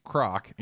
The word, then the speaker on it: Krack: K R A C K in Germany is an actual name. It is pronounced Krock in Germany, and my great grandfather decided to change it when my grandfather was born.